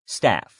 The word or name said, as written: staff